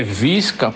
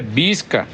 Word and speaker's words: Visca: Dizem as más línguas do Parnamirim que o nome do lugar é Visca, porque é espanhol, porque o V tem som de B e a pronúncia certa é Bisca, que vem de biscate, né?